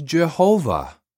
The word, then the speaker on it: Jehovah: In each language, it is pronounced differently. In English, it is usually pronounced Jehovah, but some people pronounce it Yahweh.